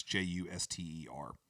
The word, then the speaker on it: Juster: and I don't know that that's how you say his name, Juster, whatever. It's Juster.